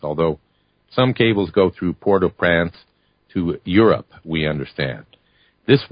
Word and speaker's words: Port-au-Prince: Eastern Standard Time, the NBC Brian Williams show had reported an earthquake measuring 7.0 on the Richter scale at Port-au-Prince, Haiti. By the way, that's the French pronunciation for those that want me to say Prince.